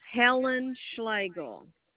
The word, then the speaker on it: Helen Schlegel: It's Helen Schlegel. – Could you spell it for me? – No. It's H-E-L-E-N S-C-H-L-E-G-E-L.